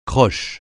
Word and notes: croche: francais

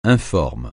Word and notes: informe: francais

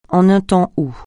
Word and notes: en un temps où: français

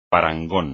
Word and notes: parangón: espagnol

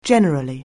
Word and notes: generally: anglais

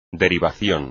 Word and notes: derivación: espagnol

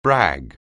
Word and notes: brag: anglais

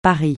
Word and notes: Paris: francais

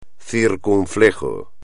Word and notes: circunflejo: espagnol

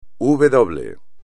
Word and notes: uve doble: espagnol

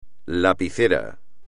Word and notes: lapicera: espagnol

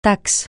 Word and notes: taxe: francais

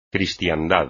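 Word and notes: cristiandad: español

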